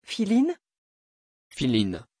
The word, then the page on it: philine: French